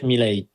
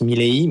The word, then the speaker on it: Milei: Alors, en espagnol d'Argentine, on prononce Javier Milei. Son nom est d'origine italienne, comme énormément d'Argentins est d'origine italienne. Donc, si on la prononce à l'italienne, ce serait plus Milei. Mais étant donné qu'il est bel et bien argentin, c'est Milei.